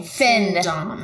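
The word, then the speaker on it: findom: Or are you saying fin-dom? – Like a shark fin. – Okay, you need to say financial. – It is pronounced fem. – F-E-M or F-I-N?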